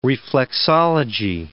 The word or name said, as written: reflexology